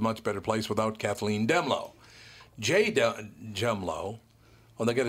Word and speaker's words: Dehmalo: We wanted to finally get the last word, Demlow said. Now, they have his name spelled the same way, D-E-H-M-A-L-O. So it's Demlow, but her name was Demlow.